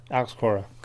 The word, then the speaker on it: Cora: It said, "Is it Cora? That's how you say it, right?" – Yeah, Alex Cora.